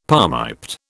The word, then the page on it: palmiped: British English